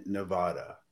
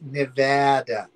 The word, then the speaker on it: Nevada: It's not pronounced Nevada. It's pronounced Nevada.